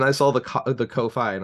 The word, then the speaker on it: Ko-fi: it's pronounced ko-fi i'm not going to lie i legitimately thought it was pronounced coffee because there I,